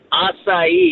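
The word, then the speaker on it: açaí: And how do you pronounce, is it acai? – Acai. – Acai. – If you want to get precise.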